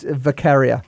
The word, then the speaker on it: Vakharia: It's Vakharia.